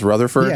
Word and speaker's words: Rutherford: Your name is pronounced Rutherford? – Yeah, that's correct.